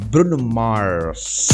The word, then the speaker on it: Bruno Mars: Pronuncia assim, Bruno Mars.